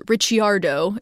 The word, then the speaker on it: Ricciardo: Technically, his name should be pronounced Ricciardo if we were going by the Italian pronunciation, but Daniel, the Australian, does not care.